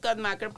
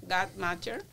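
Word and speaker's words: Guttmacher: Bueno, según el instituto Godmacher, en, en, inglés es Godmacher, pero en español se pronuncia Godmacher dice que está,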